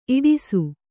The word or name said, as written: ಇಡಿಸು